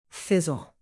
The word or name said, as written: fizzle